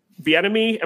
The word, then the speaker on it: Bieniemy: I mean, we've all seen how good that Kansas City roster is, and you know, he could get paired with Bieniemy. Am I pronouncing that right? – Yeah.